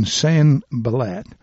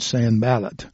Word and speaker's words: Sanballat: But it came to pass that when Sanballat heard, and let me repronounce that, Sanballat heard that we builded the wall, he was wroth and took great indignation and mocked the Jews.